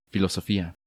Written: Filosofia

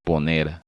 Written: Poner

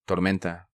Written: Tormenta

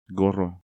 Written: Gorro

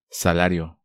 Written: Salario

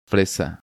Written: Fresa

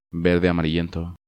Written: Verde amarillento

